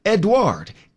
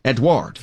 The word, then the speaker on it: Edouard: E-D-O-A-U-R-D? – Yeah, close. Edward. E-D-O-U-A-R-D. – Ah. – Edward. – See, if I'd written it down, I would have been able to do it. – Tropical Storm Edward.